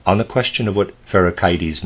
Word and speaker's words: Pherecydes: Chthon is spelled C-H-T-H-O-N. And I would like to say I'm going to pronounce a word Pherecydes, that's how I was pronouncing it before. I've decided I want to pronounce it Pherecydes, and Syros is S-Y-R-O-S.